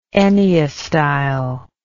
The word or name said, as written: enneastyle